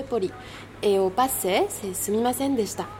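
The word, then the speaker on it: Sumimasen (すみません): On dit bien Sumimasen? – Sumimasen, oui. Tu le prononces très bien.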